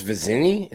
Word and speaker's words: Vizzini: How long ago was it when we were like Chris Vizzini? Is that how you say it? – Yeah, Vizzani committed to Clemson.